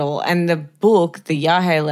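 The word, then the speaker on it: yagé: Or yahe. I'm pretty sure it's yahe, but it's like Y-A-G-E, but I think it's pronounced yahe.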